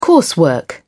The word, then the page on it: coursework: British English